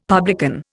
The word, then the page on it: publican: Indian